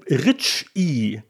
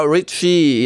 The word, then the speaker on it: Reiche: aber die Rich I sei die korrekte Aussprache. Genau, Richie, ja genau, aber also mit langem I meines Wissens,